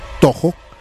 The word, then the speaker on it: Toho: Los estudios Toyo. ¿Es Toyo o Toho? ¿Cómo se dice en español? – Toho. – Ay, los estudios Toho.